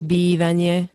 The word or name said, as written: bývanie